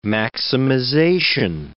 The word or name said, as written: maximization